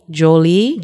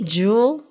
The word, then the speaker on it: Joule: It's J-O-U-L-E, Jolie. – Jewel. – Ooh. I know it's probably Jewel. You're probably right. – Nope, we're going to be... Jewel. Someone's going to come back. – It probably is Jewel. – Well, that's how you spell Jewel. – J O U L E? – Like energy jewels, right? – Oh, I didn't know that.